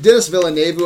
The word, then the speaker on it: Denis Villeneuve: And Denis Villeneuve, I think that's how you say his name. Yeah. It's a tricky one.